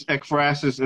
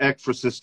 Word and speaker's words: Ekphrasis: Ekphrasis? That's how you pronounce this? – Yeah, it's Ekphrasis. And I've heard it Ekphrasis too. So both ways.